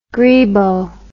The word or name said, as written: greebo